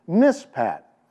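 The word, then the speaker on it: mishpat: So the justice, word for justice in Hebrew is pronounced mispat, and it was replaced with bloodshed, mispo.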